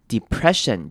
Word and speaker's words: depression: 首先第一个重点呢是第一个字 depression，就是忧郁这个字。这边要注意的是开头的 e，我们可以念成长母音一的声音 depression，或者是你也可以念成轻音 depression，两种声音都是可以的。那注意到后面的 ss，我们会念成 sh 的声音，就是 sh 的声音，所以是 depression， depression。